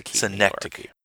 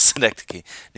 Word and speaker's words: Schenectady: It reminds me of a Hoffman film like Schenectady, New York, or whatever, however the hell it's pronounced. – Schenectady. – Yes, Schenectady, New York.